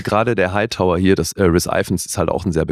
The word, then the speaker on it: Rhys Ifans: Also Rhys Ifans wird er ausgesprochen. – Rhys Ifans, ja.